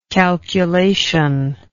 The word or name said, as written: calculatory